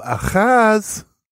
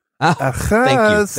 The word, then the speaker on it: Ahaz: It's actually pronounced dial of Ahaz. – Ahaz.